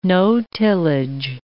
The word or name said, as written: no-tillage